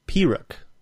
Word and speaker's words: Pyrrhic: Pyrrhic. – Pyrrhic. – Well, the emphasis is on P-E-E, Pyrrhic.